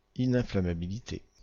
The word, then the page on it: ininflammabilité: France; IPA: /i.nɛ̃.fla.ma.bi.li.te/